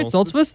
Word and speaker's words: Stoltzfus: Am I saying that right? Stoltzfus? Uh-huh. All right, Stoltzfus here.